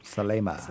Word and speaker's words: Salema: Is it Salema Beach or Salama Beach? How do you pronounce it? – Salema. – Salema. Okay, thank you. I was wrong on both counts.